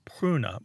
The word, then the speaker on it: pruna: But and that word was pruna. Martha, how's my pronunciation on Latin? It's P-R-U-N-A.